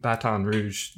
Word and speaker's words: Baton Rouge: I had one other American friend who was from Baton Rouge, Louisiana.